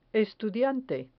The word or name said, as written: estudiante